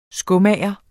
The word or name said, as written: skomager